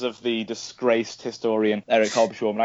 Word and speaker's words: Hobsbawm: I say, like, swarm. like swarm, but, like, with a schwarm, like, Hobsbawm. But, like, that's Sean Connery, yeah. – Hobsbawm.